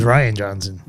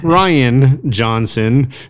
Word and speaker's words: Rian Johnson: Written and directed by Rian Johnson, who also did... – That is Rian Johnson. – Is it Rian Johnson?